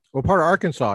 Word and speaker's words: Arkansas: To Arkansas, where I'm from, and wrote my dissertation there for a year. – I think it's pronounced Arkansas.